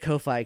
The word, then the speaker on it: Ko-fi: I just pronounce it Ko-fi because it's like Wi-Fi, but not.